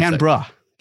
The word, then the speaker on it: Canberra: I hope I pronounced Canberra. – That. Canberra. Yes. Oh, that's right. Canberra.